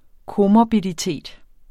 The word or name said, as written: komorbiditet